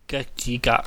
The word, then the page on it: geukjigaak: Cantonese